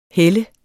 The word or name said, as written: helle